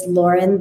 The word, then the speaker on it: Loren: My handle is Loren the Activist, and my name is spelled with the masculine spelling. It's L-O-R-E-N, not L-A-U-R-E-N.